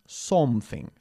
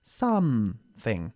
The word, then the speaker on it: something: Ojo con la pronunciación también de la palabra something. No se dice something, sino something, okay? Bajando la mandíbula, como siempre decimos.